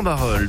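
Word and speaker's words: Barœul: Pourquoi Eh bien pour nous apprendre, ou plutôt pour apprendre aux personnes du sud du PH d'Arras à bien prononcer Barol. Eh oui, il n'y a pas de I, et ça il va nous le dire comme il se doit.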